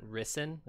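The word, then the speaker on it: Rysn: And then odd, primarily for the first interlude chapter with, is the character's name Risen or Risen? – Risen is how they say it. – Risen? Okay. – Yeah, it sounds like two syllables, like Risen is how they say it. – Okay. The Thalen names definitely throw me. They sound odd. But Rissen. Okay.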